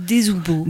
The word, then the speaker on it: Desoubeaux: Je ne sais pas si je prononce bien Desoubeaux. – Moi je dis Desoubeaux.